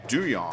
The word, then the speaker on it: Duhon: So Andrew Duhon's name is actually pronounced Duyon.